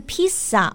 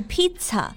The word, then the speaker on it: pizza: 对，像 pizza 我们不是 pizza，而是 pizza。